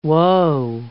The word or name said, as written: woe